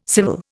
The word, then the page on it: civil: Indian